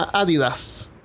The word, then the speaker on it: Adidas: Y la otra marca bien popular es Adidas, que se pronuncia Adidas. O sea, se debería pronunciar Adidas, así es el nombre original.